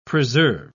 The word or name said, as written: preserve